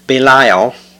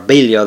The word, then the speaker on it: Belial: So the first verse of chapter 20 says, and there happened to be there a man of Belial, or Belial that can be pronounced, whose name was Sheba, the son of Bichri, a Benjamite.